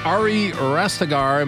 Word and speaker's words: Ari Rastegar: And we are on with Ari Rastegar.